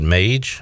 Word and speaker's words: Mage: And you continue to say Madge. I'm pretty sure they pronounced it Mage on the broadcast. – It's Mage. Yeah. There's no D in there.